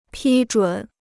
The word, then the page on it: 批准: pī zhǔn